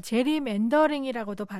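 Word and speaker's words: gerrymandering: 이게 이른바 그 이 게리맨더링하고 관련이 있는 거죠? – 네, 맞습니다. 이 미국인들은 뭐 제리맨더링이라고도 발음하는 건데요.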